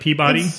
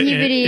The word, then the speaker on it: Peabody: It looks like Peabody. But Peabody. If this is the Peabody of Massachusetts, that's the way people in Massachusetts pronounce it.